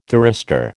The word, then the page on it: thyristor: American English